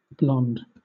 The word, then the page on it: blond: British English